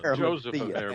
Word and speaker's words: Arimathea: Joseph of Arimathea. – And that's exactly right. – And that's exactly right. You said it right, and that's where he was from.